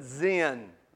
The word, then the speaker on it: Zin: Some of your translations will pronounce that Zin, Z-I-N.